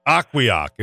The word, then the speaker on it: Ocqueoc: Let me spell it for you because it's odd. It's OCQUEOC. Aquioc.